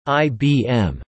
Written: IBM

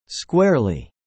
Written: squarely

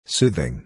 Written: soothing

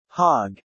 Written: hog

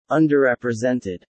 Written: underrepresented

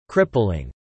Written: crippling

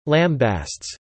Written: lambastes

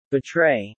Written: betray

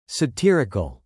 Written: satirical

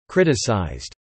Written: criticised

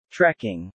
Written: Trecking

